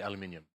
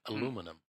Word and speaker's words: aluminium: ああ Well I say aluminium. Americans say aluminum.